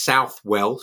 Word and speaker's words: Southwell: But my favorite is Upon the Image of Death by the Jesuit poet and martyr Robert Southwell, Saint Robert Southwell, actually pronounced Southwell, so I'm not confusing people.